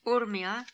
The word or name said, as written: Urmia